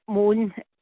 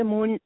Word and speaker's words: Mone: it's Lynn Frances Moan. The Moan, Lynn is spelled LYNN, and Frances, and Moan is MONE.